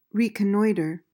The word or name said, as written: reconnoiter